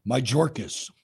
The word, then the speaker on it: Mayorkas: Mayorkas. – First name. – Alejandro. Hey, you know what? Speaking of him real quick before I forget, I saw something this morning that Mayorkas personally refused RFK Jr. Secret Service.